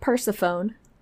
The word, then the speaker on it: Persephone: It's spelt Persephone. – I was going to say, it's not pronounced like xylophone.